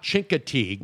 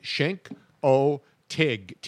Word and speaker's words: Chincoteague: If you're a local. – Yes. – It's not Chincoteague. – No. We were saying it wrong all these years, Tony Bruno. – But a guy finally had a t-shirt on that said S-H-I-N-K, Chink-O-Tig, T-I-G. – Right. – So that people pronounce it properly.